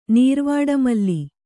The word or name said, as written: ನೀರ್ವಾಡಮಲ್ಲಿ